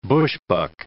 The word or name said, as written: bushbuck